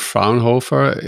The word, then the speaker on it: Fraunhofer: You're working with CertX and Fraunhofer AIIS.